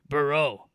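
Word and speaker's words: Burreaux: It's spelled Burrow, and on his senior night he came out in a jersey that was spelled Burrow the Louisiana way. Burrow. EAX. – So he's EAUX.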